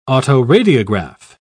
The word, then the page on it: autoradiograph: British English